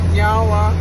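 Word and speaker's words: niawen: So, in our language, we say nyawa, which means thank you. – Nyawa. – Nyawa. – I said it right? – Yeah, you did.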